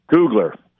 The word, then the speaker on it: Kugler: Kugler.